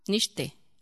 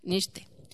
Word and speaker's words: Nietzsche: Nietzsche. Ay, conste que sí investigué cómo se pronunciaba, Nietzsche.